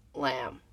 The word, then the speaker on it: Lam: named Lamb, spelled the same way, L-A-M.